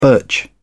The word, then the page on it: birch: British English